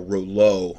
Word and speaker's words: Rouleau: In the blood, there appears to be ongoing oxidative stress and Rayleigh formation, or Rouleau. I'm sorry, somebody pointed out to me I was pronouncing it wrong.